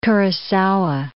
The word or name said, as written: Kurosawa